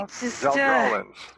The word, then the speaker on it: Saldralans: and underlines Saldralans with the phonetic pronunciation. – Right, Saldralans.